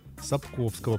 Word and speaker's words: Сапковского: Читал ли Андрей повесть небезызвестного Анджея Сапковского, правильно я произношу, да? – Змея.